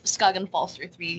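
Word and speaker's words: Skagen Falster 3: Am I saying that right? – Skagen Falzer 3, yeah.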